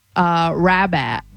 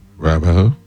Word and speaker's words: Rabat: Rabat. – Rabba. – Rabba. Rabat. – Spell it. – R-A-B-A-T.